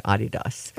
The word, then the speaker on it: Adidas: What Adidas gave up, I think, totally was the, and it's actually pronounced Adidas.